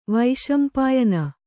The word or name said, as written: ವೈಶಂಪಾಯನ